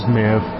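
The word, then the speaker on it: Maedb: Yes, this is Maeve, a beautiful green and black engine.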